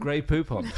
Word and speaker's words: Grey Poupon: Grey Poupon. – Grey Poupon. To rhyme with coupon.